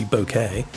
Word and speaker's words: bokeh: When it's alphabetized, it's actually only B-O-K-E. The H was added to help non-Japanese speakers understand the correct pronunciation. Yet, there are two camps. Since Wikipedia says it should be bokeh, that's what I'm sticking with.